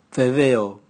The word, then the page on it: veveo: Raga